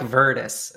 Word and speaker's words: virtus: I like virtus. I like the Beaumont pronunciation. Cool. But it's virtus.